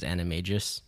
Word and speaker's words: Animagus: How do you pronounce this? Animagus? Animagus?" – Um the correct pronunciation is animagus.